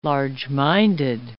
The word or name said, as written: large-minded